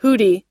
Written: hoodie

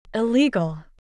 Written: illegal

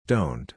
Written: dont

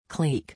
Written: clique

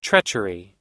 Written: treachery

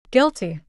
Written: guilty

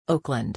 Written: Oakland

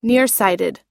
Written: nearsighted

